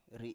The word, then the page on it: ri7: Biak